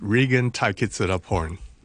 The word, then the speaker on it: Regan Taikitsadaporn: Good morning, Regan, and have I got your name right? – Almost. – Go on, give it to me properly. – It's Regan Taikitsadaporn.